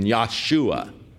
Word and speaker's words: Yahshua: Yah, actually, in Hebrew, the J has a Y pronunciation, Yahshua. The name Jesus is the Greek form for the Hebrew name Yahshua.